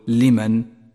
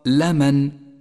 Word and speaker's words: لمن: واحذر من اللحن في كلمة لمن، فاللام مفتوحة وليست مكسورة، فلا تقل لمن، بل الصواب لمن، مدحورا لمن.